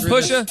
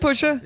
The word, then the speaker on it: Pusha: It's funny, you say it like his name is spelt E-R. – Pusha. I said Pusha? – Yeah, but you put a little Southern white boy on it.